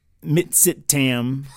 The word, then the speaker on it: Mitsitam: The Mitsitam. Sounds exactly right. Native. Say that like you're a Native American. It was the Native Foods Cafe. – Mitsitam.